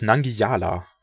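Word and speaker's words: Nangijala: Okay, wir sowieso gerade auch über, also ihr müsst mir ganz kurz mal sagen, wie spricht man die letzte Single eigentlich richtig aus? – Das ist Nangiala. – Okay, gut. Ich habe das J immer als J quasi gelesen.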